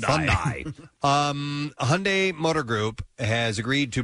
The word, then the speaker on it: Hyundai: Which, by the way, if you ever say the word Hyundai, I've been told by the corporate directive is Hyundai rhymes with Sunday.